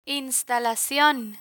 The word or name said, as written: instalacion